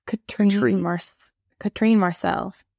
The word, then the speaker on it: Katrine Marçal: And it's by Katrin McCall, or how do you pronounce her name? – I think it's Katrin Marcel.